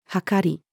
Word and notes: 秤: female

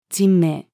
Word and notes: 人命: female

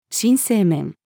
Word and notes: 新生面: female

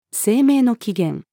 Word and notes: 生命の起源: female

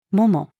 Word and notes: 桃: female

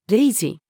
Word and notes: 零時: female

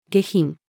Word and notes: 下品: female

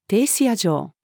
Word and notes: 停車場: female

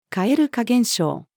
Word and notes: 蛙化現象: female

